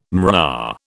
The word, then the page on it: mRNA: British English